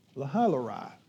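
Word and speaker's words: Lahai-roi: It's a well called Lahailarai. Lahailarai actually is the true pronunciation.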